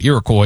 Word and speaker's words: Iroquois: They were taking on Iroquois tonight. I believe I'm saying that right. Iroquois.